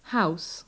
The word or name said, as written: house